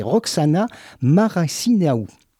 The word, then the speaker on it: Roxana Maracineanu: La nouvelle ministre des Sports, vous savez, c'est Roxana Maracineanu. C'est difficile à prononcer, je recommence. C'est Roxana Maracineanu qui a succédé à Laura Flessel, a assuré depuis qu'aucun CTS ne perdrait son poste.